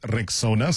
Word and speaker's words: Rexona: It is pronounced Rexona, simple, strong, and easy to remember.